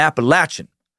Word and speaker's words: Appalachian: all pronounce it as follows. Appalachia and Appalachian.